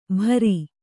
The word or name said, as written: ಭಾರಿ